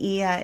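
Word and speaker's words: Oia: And Ia is spelled O-I-A. I know it took me a while to get the pronunciation of the words because of the spelling was unfamiliar to me.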